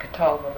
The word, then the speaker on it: Catawba: No, there is a little difference, but then we use a Catawba worm, and we pronounce it Catawba, but it isn't spelled that way.